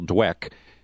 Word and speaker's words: Dweck: It's Carol Dweck. There's no R in there.